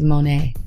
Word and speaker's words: Monay: Oh, my name is Monet. It's spelled like Monday, but without the D.